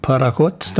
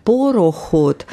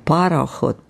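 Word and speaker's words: Parachot (пароход): Und gerade gehört, vorhin Leonid Utyosov mit Parachot, Dampfboot. – Ja, nur schreibt man nicht Porochot, sonst wäre dann Porach, Pulver, dann diese Schießpulver. Und Parachot, Par, wie Dampf.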